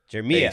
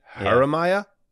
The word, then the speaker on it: Jeremiah: Jeremiah. Soft J. Jeremiah.